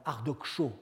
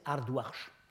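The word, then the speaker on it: Ardoxsho: qu'on dit généralement Ardoksho, parce que c'est comme ça que ça s'écrit en bactrien. En fait, ça se prononçait Ardouarch.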